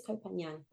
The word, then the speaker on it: Koh Phangan: I think it's Koh Phangan, but I could be pronouncing it wrong. – You're right. I did a phonetic spelling here in my notes and that was right.